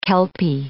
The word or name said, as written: kelpie